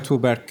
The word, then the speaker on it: Tuğberk: Koray Tuğberk Gübür or Koray, Koray, Koray.